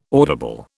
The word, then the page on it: audible: British English